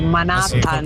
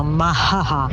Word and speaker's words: Manhattan: Cioè, alcune veramente, cioè, tipo il Manhattan, ma sì, alcuni lo pronunciano Mahaha.